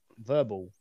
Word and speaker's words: Vurbl: It is VURBL, Verbal.